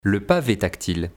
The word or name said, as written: le pave tactile